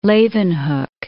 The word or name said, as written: Leeuwenhoek